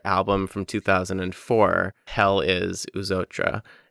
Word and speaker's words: Eux Autres: It's Eux Autres,